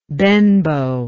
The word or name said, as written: benbow